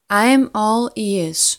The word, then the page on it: Im all ears: slow